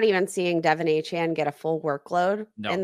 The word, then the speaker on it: Achane: Devin Achane, or Achane, I believe is how you say it. Achane.